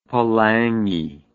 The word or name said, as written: palagi